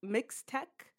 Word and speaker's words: Mixtec: Yeah, so Mixtec. Mixtec. I believe is how you pronounce it.